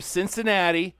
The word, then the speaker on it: Cincinnati: I want to drive from Cincinnati up to Cleveland. – Okay. – Did I say that right? – Yes, you're right.